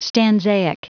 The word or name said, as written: stanzaic